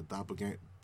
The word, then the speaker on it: doppelgangers: You know, the doppelgangers or doppelgangers? How do you pronounce that? – Doppelgangers. – Okay. Doppelgangers, you know, gangers.